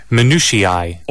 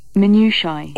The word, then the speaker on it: minutiae: I say minutiae, but you can say it this way. – Minutiae. – Or? – Minutiae.